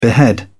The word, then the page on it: behead: British English